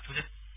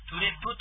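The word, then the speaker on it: トゥレㇷ゚: だから、トゥレッという発音になります。トゥレッ。最後、ちっちゃいプが入るというね。 – ちっちゃいプはどういうふうに発音したらいいですか、ね、トゥレッ。 – トゥレッ。 – はい、そうです、そうです。ちっちゃいプなんで、口を閉じるだけみたいな。トゥレップとかトゥレップとかね。そういう発音でなく、トゥレッ。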